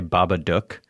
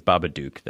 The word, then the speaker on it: Babadook: They pronounce it rhymes with book. They say Babadook. – Yeah, I like that. And I was like, no, that says Babadook.